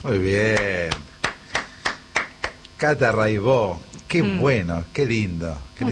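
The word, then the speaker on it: Raybaut: Se escribe Raibaus, métanse, búsquenla. Rai con Y, Baut con B larga. Catalina Raibaut, Raibó se dice.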